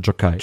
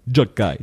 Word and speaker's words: Gjokaj: G-J-O-K-A-J. Würde ich sagen, das spricht man Jokai. – Jokai.